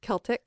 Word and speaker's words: Celtic: So I need to get, it's a hard C, by the way. – Celtic.